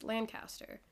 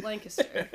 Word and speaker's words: Lancaster: It's not pronounced Lancaster. It is pronounced Lancaster.